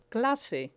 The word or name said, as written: clase